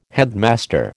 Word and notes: headmaster: American English

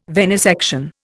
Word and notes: venisection: Indian

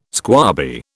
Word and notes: squabby: British English

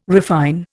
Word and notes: refine: Indian

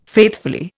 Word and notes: faithfully: Indian

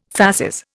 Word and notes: fasces: Indian

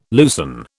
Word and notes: loosen: British English

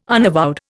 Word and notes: unavowed: Indian

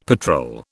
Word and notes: patrol: British English

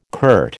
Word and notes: curt: American English

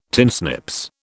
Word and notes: tinsnips: British English

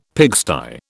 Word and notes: pigsty: British English

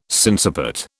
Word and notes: sinciput: British English

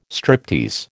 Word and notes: striptease: American English

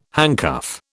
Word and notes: handcuff: British English

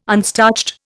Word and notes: unstarched: Indian

